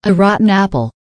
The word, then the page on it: a rotten apple: American English